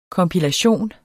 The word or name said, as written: kompilation